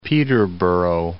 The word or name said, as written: Huntingdon and Peterborough